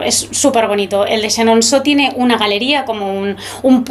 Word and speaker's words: Chenonceau: Chenonceau, muy bien, muy buena la pronunciación, Bueno, sí.